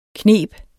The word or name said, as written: kneb